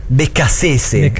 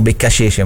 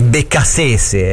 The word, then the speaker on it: Beccacece: Ma la storia della notte ce l'ha regalata, possiamo anche vedere una bellissima immagine, il CT dell'Ecuador, Beccasese. Ieri lo chiamavamo Beccacece, ma questa è una pronuncia da fucecchio, no? È Beccasese, è il CT.